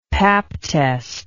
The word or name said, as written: pap test